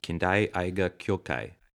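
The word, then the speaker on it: Kindai Eiga Kyōkai: David, it's been a long time since I studied Japanese in college, but I'm pretty sure that the film company he started is pronounced Kindai Aiga Kyokai.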